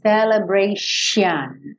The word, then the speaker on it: celebration: Escreve com T, mas você vai falar esse T como se fosse um X. Então, esse, X, esse T-I-O-N vai soar X-I-O-N, celebration, ok?